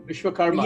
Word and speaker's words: Vishvakarman: People imagine these as wonders that were created by engineering gods like Hephaestus in the Greek myth, and you'll have to tell me if I'm mispronouncing this, but Vishvakarman. – Vishvakarman, yes.